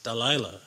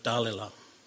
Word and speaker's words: Dalila: And I demanded that he pronounce the name of Delilah as Delilah, as we would all expect to hear it, when in fact he proved to me instantly that Milton actually rhymed it Dalilah, that he scanned it Dalilah, that he was a Hebrew scholar.